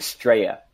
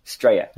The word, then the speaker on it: Straya: They're saying Straya, which is Australian for Australia. Straya.